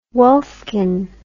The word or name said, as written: wolfkin